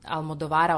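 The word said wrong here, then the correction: Almodóvara: odnosno Almodovara, kako se pravilno akcentuje, a to nije slučajno.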